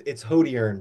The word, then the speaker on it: Hodierny: Yeah, a little bit, but it's Hodierny, but you'll get it.